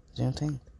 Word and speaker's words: Juneteenth: Juneteenth. That's how you say it. Juneteenth.